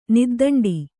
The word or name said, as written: ನಿದ್ದಂಡಿ